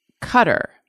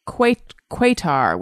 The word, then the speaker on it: Qatar: Mr. Boinkity says, I think it is weird that the country Qatar is pronounced Cutter. I grew up with it being called Quatar, which I like. How do you guys pronounce it? Q-A-T-A-R.